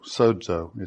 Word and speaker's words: sozo: Now, I told you last week that the word in the Greek for saved is sozo, sozo is how it's pronounced.